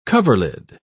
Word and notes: coverlid: British English

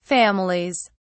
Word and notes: families: British English

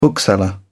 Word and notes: bookseller: British English

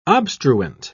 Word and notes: obstruent: British English

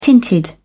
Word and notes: tinted: British English

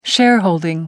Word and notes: shareholding: American English

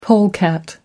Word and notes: polecat: American English

